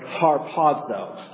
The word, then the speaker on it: harpazo: So Paul uses the form of the verb harpazo. I'll spell it to you, transliterate it into English. H-A-R-P-A-Z-O.